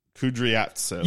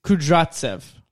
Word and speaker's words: Kudryavtsev: But the way to pronounce that name, let me see it again. – Kudryatsev. – You don't pronounce the Y. So it's Kudryatsev.